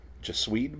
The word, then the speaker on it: Gesswein: Richard Gesswein, or Guess... Wow. It's G-E-S-S-W-E-I-N. – Gesswein, maybe? – Just, yeah.